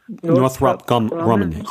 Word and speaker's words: Northrop Grumman: I was sitting next to the CEO, and I'm going to mispronounce the name of the company, Northrop Grumman. Is that how you say it? – Northrop Grumman, yes.